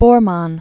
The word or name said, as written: Bormann